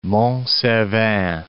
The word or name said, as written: Mont Cervin